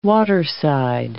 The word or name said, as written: waterside